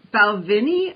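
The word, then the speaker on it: Balvenie: So right now we have a bottle of Balvini open.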